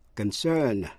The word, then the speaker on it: concern: Hai từ nữa là concern, C-O-N-C-E-R-N, là quan tâm hay là liên hệ đến, và consent, C-O-N-S-E-N-T, nghĩa là thỏa thuận. Dù là danh từ hay động từ đều nhấn vào vần nhì và phát âm y hệt nhau.